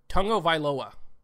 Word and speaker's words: Tagovailoa: Tagovailoa. That's how you pronounce it.